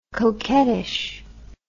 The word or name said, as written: coquettishness